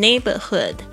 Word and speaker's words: neighborhood: OK, 他说呢，我会选一只狮子 Lion, L-I-O-N, lion, 狮子 But it's quite dangerous for the neighborhood. 但是呢，这个相当危险哦，特别是对我们的小区宁静环境 neighborhood. N-E-I-G-H-B-O-R-H-O-O-D.The neighborhood, 特别注意一下 GH 在这边是没有发音的 It's quite dangerous for the neighborhood.